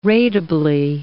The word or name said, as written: ratably